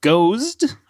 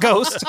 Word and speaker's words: Gozde: Yeah, that was Believe by John Gozd, I believe is probably how you say it. Not Ghost. G-O-Z-D-E.